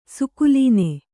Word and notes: ಸ್ಖಳನ: kannada

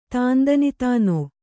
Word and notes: ತಂದಾನತಾನ: Kannada

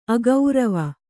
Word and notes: ಅಗೌರವ: agaurava

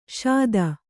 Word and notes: ಷೋದಾ: Kannada

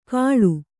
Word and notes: ಕಳು: Kannada

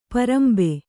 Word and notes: ಪಾರಂಬೆ: pārambe

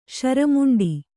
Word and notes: ಶರಮುಂಡಿ: śara muṇḍi

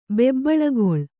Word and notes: ಬೆಬ್ಬಳುಗೊಳ್ಳು: bebbaḷugoḷḷu

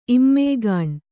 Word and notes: ಇಮ್ಮೆಯ್ಗಾಣ್: Kannada

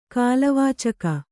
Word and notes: ಕಾಲವಾಚಕ: kālavācaka

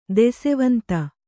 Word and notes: ದೆಸೆವಂತ: Kannada

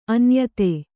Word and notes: ಅನ್ಯಥಾ: Kannada

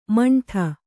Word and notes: ಮಂಠ: maṇṭha

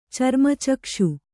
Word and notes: ಚರ್ಮಚಕ್ಷು: Kannada

